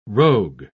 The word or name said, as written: rogue